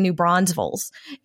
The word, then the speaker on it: New Braunfels: It is German, but nobody I know pronounces it that way. We say New Braunfels, and there's no extra S in the middle.